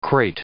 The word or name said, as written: crate